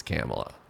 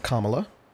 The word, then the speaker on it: Kamala: When I think about like who do I want to deal with a potentially combative Republican Senate, it's Kamala. – Yeah. Like, also, it's Kamala. Like comma, C-O-M-M-A. Think of it like that.